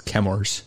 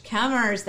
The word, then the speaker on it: Chemours: called Chemours. – I think it's Chemours. – Chemours. That makes more sense. – Like vapors and chem. – Yep, Chemours. Chemours. So they made a spinoff company called Chemours, which has taken over all the manufacturing of Gen X.